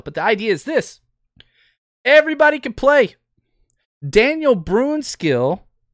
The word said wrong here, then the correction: Brunskill: Brunskill. I know I say it wrong.